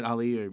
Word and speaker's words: Ali: I go, hey, guys, my name is Ali, or you can pronounce it Ali. Or anyway, you can call me whatever you like.